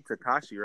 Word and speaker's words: Tekashi: So, it says, how do you say his name? Tekashi, right? – Yeah.